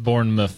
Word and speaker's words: Bournemouth: Spelled Bournemouth, but pronounced Bournemouth.